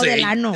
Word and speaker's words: Delano: Pero se dice Delano, ¿no? – Ah, yo no sé. – No, Delano. – Ah, yo no sé. – Se escribe así, digo, porque a mí me corrigieron un montón de veces. – Ay, hombre, sí. Yo soy mexicano. ¿Delano? – Sí. – Aquí dice Delano. O sea, yo como mexicano, ¿cómo lo escribiría? ¿Cómo lo pronuncio? Delano.